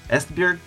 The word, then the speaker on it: Esbjerg: It's Esbjerg, and joined Maersk as a cadet at the age of 16.